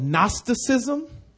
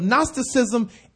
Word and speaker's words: Gnosticism: And it is also a combination of Gnosticism and pantheism. Gnosticism, G-N-O-S. I didn't put it up there. G-N, the G is silent. G-N-O-S-T-I-C-I-S-M.